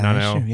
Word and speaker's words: Nanao: Nanao, Ao, like. – Yes. Yeah. Nanao.